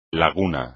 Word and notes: laguna: espagnol

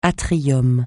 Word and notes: atrium: francais

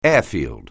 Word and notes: airfield: English

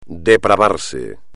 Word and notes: depravarse: espagnol

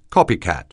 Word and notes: copycat: anglais

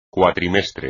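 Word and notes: cuatrimestre: español

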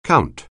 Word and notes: count: anglais

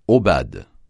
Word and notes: aubade: francais

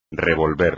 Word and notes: revolver: espagnol

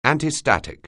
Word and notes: antistatic: anglais

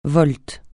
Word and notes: volte: francais

